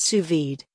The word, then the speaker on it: sous vide: Sous vide, pronounced sous vide, is French for under vacuum.